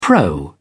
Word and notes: pro: English; British English

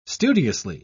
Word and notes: studiously: British English

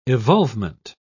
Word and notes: evolvement: British English

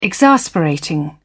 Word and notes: exasperating: British English

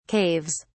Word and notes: caves: British English

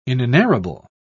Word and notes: inenarrable: British English

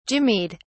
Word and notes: jimmied: British English

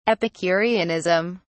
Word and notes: epicureanism: British English

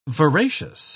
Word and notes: veracious: British English

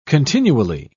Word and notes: continually: British English

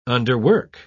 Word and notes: underwork: British English

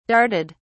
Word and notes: darted: British English

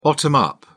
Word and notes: bottom-up: British English

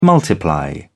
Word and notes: multiply: British English

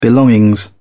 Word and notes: belongings: British English

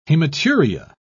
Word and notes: hematuria: British English

